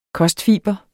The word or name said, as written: kostfiber